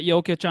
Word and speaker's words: Jokic: Jokic.